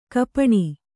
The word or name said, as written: ಗೋಪನ